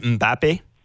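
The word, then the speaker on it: Mbappé: Mbappe? – That's right.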